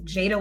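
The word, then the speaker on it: Jaida: It's J-A-I-D-A-L-O-P-E-Z. Jada with an I. Not a Y, not J-A-D-A, J-A-I-D-A-L-O-P-E-Z.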